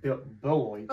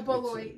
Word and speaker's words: Beloit: I don't know how to pronounce it, but I want to say Deloitte. – Well, Beloit. – Oh, Beloit, yes. It's a B. It's, dyslexic, sorry. DB and I picked Lake Forest. You picked Beloit.